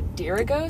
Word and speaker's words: Dirigo: Anyway, and then the race that we had was called the Dirigo Sprints. – Is that how you pronounce it? I can never spell it right for some reason. – D-R-I-G-O. – D-R-I-G-O. – Drigo? – No, D-I-R-I-G-O.